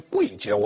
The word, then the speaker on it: 会稽: 在会稽一带。这个字也念贵稽，我不会念，但我们都念会稽，现在查字典就不知道怎么念啊。